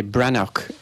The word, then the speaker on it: Breathnach: Leur nom en irlandais n'a rien à voir avec le mot Walsh, et c'est Brannoch, et ça s'écrit B-R-E-A-T-H-. N-A-C-H.